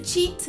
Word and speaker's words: cheat: Então por isso cheat significa trapacear e tem essa pronúncia de ch, cheat.